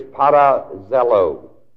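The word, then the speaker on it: parazelo (παραζηλόω): The Greek word is parazelo. The A-R-A, a preposition, and zelo, Z-E-L, O-O, long E, long last O.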